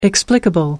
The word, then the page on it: explicable: American English